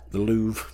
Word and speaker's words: Louvre: How do you say that? – The Louvre. – The Louvre. – No, actually that's right. Say Louvre.